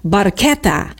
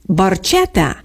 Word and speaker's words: barchetta: The correct Italian pronunciation uses a hard K, barchetta, meaning little boat in Italian. However, Geddy Lee famously sang it with a soft ch, barchetta.